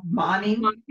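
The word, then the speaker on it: Moning: Monning? – Yeah. – Karen Monning, brilliant writer.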